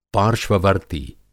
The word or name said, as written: पार्श्ववर्ती